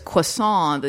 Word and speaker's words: croissant: That Beth Bear's character goes, "Oh, it's croissant, the T is silent."